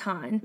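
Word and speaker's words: HONNE: Well, I wanted to look up how to pronounce the group because mm-hmm. I wasn't sure because it's H-O-N-N-E, mm-hmm. all caps. I was like, well, I'm not sure. So we looked it up and it's pronounced Han.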